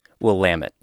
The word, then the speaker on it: Willamette: Willamette, yes. – Willamette, okay. – Yeah, we'll excuse the pronunciation error.